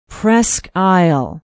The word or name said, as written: presque isle